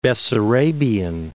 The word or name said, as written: Bessarabian